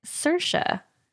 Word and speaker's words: Saoirse: but it's spelled S A O I R S E. S A O I R S E pronunciation. Let's see. you say Sersha. Sersha, yes.